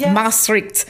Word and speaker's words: Maastricht: I would love to hear about you, Melinda, an opera singer and comedian, having attended the Maastricht, am I saying it correctly? – Yes. – Maastricht Conservatory of Music, the Royal College of Music, and Brussels Opera Studio.